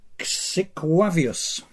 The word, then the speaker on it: Xyqwavius: If you think Chemirmir is hard to say, try this one. Xiquavius. I think I said it right. The spelling is Xyqwavius.